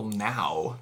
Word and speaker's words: Nau: I have a song by Michael Now that's spelled N-A-U, which I think is the pronunciation. – Now.